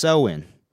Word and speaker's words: Samhain: Well, it's really called Samhain, but you better not pronounce it that way because it's pronounced so-in.